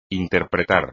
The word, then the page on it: interpretar: Spanish